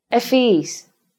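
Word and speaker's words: ευφυής: then we don't pronounce the V or F sound twice or longer. We pronounce it once, as if the Ypsilon of the double vowel combination wasn't there. Listen again. Evia. Efis.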